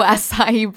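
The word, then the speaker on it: açaí: How do you say them? – Things? What's the word? – Acai bowls. – Oh, acai bowls.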